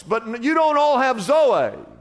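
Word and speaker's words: Zoe (ζωή): It says, I've come to give you, and He uses the word Zoe, Z-O-E, but it's pronounced Zoe.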